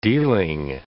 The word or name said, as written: dealing